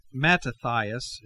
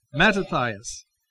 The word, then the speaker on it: Mattathias: And soon after the death of Matthias, leadership, Mattathias is a proper pronunciation. I'm sorry, I have been shortening it. His friends call him Matthias. No, Mattathias. Mattathias.